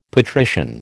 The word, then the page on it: patrician: American English